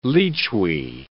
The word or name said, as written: lechwe